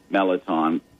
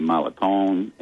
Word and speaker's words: mirliton: It is spelled M-I-R-L-I-T-O-N, and that's a French word. It probably, it's only called a melaton. I pronounce it melaton. In parts of Acadiana, it would be Malaton, and sometimes people actually call it Merlaton. – Or in St. James Parish, where I grew up, it's Meliton. – Meliton. T-A-W-N.